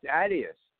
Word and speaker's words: Adidas: Adidas bought out Reebok, but it's pronounced Adidas. The correct pronunciation is Adidas.